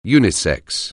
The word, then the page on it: unisex: anglais